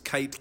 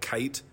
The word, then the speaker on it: Kyte: My last name's Kyte, K-Y-T-E. If you Google Greg Kyte, or Kyte spelled with a Y, it's like the first few pages are just me.